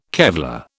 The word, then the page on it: Kevlar: British English